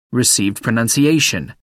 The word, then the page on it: received pronunciation: American English